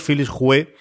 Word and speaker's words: Philips Hue: Philips Hue HomeKit GU10.